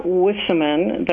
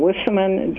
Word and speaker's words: Wisseman: Sarah Wissaman, that's Sarah with an H, S-A-R-A-H, and then Wissaman, W-I-S-S-E-M-A-N, and I have a website with that name.